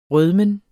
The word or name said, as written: rødmen